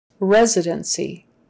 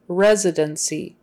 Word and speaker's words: residency: Residency. That S is a Z sound. Residency. The C is an S sound. Residency. Not residency. Residency. It's residency.